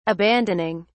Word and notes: abandoning: British English

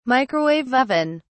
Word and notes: microwave oven: British English

